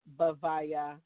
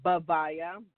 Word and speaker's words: Bhavaya: So the Shatavana is spelled with an S-H-A-R-A-V as in Victor, A-N as in Nancy, A. So Shatavana, and then Bhavaya, B-H-A-V as in Victor, A-Y-A, Bhavaya.